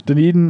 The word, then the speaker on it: Dunedin: City of Dunedin. Hey, I pronounced it right tonight. – You did. You nailed it. – I'm not drunk. Dunedin High School Scottish Highlanders Pipe Band.